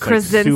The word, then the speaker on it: Krasinski: It's Krasinski. Like super easy. It's exactly the way it looks.